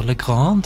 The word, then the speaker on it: Legrand: Så skal vi til Nora Legrand, hvordan det med uttale etternavnet? Hun har selv sagt at du kan velge hvordan du vil gjøre det, men mora hennes fransk, så Legrand jo faktisk rett, men du kan si det akkurat sånn som du vil.